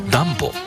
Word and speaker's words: DUMBO: ニューヨークを知っている方でもダンボという名前を聞いたとき、少し首をかしげることがあります。 DUMBO と書いてダンボと読みます。